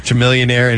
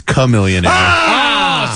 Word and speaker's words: Chamillionaire: Chamillionaire and his chitty. – I'm getting some emails and texts saying that my pronunciation is incorrect, that it is Chamillionaire.